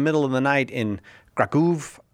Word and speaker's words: Kraków: Pronounced in the United States, Krakow, but not everybody has my kind of high-quality Polish pronunciation, so you can say Krakow if you want, and you are welcome to call.